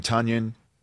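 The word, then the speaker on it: Tonyan: With Davante Adams and then Lazard and Robert rhymes with Funyun Tunyon.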